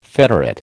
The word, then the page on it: federate: American English